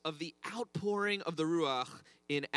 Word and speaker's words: Ruach: Everyone say Ruach. If you're not spitting on the person in front of you, you're not saying it right. Try again. Ruach.